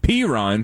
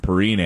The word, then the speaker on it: Perine: But P-Ron, thank you to the texter who corrected me the other day. I called him Perine, and I meant to pronounce it properly, but I got it wrong.